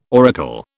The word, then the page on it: auricle: British English